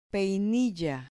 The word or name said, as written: peinilla